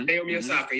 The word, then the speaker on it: Naomi Osaka: Naomi Osaka. Am I saying her name right? The tennis sister. Mm-hmm. – Naomi Osaka, yes.